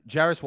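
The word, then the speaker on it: Jarace: How do we say his first name? I meant to ask you. – Jairus Walker. – Jairus.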